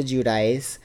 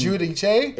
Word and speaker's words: Giudice: It's Giudice. It's not Giudice? – No. That's like the American way to pronounce it. It's Giudice. – Oh! – Giudice is like the Italian way to...